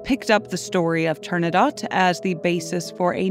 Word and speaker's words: Turandot: And if you're more familiar with the opera and you're wondering why I'm pronouncing Turandot with a T, it's because the word is Persian and Puccini, like me, wasn't great at pronunciation. But in this case, the Persian word is Turandot.